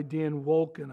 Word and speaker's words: Dan Wolken: Recently, there was an article in USA Today written by Dan Wolken, I think is how you pronounce it, W-O-L-K-E-N.